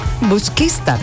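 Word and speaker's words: Busquístar: Busquistar. – Busquistar, el acento. Me faltaba el acento, busquista.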